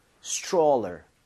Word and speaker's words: stroller: 읽을 때 여기 TR mom 이렇게 되면 얘가 T하고 R하고 충돌을 하면서 치읓 비슷해집니다. 그래서 스트롤러가 아니라 입모양을 좀 모으면서 stroller 이렇게 해서 발음을 해보는 거예요.